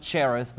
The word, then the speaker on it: Cherith: and we went down to a place called Carith, as many pronounce it Cherith, but we got on the bus.